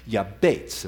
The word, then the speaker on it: Jabez: Jabez, or his actual Hebrew pronunciation of his name, is Yabetz, and we'll be exploring his name a little bit.